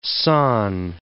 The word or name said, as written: San